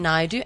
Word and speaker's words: Naidoo: It's Sashi Naidu. And there's a bit of confusion. It's spelled S-H-A-S-H-I, but it's pronounced Sashi. And then my surname's Naidu, N-A-I-D-O-O.